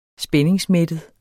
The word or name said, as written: spændingsmættet